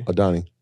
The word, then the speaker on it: Adani: Adani.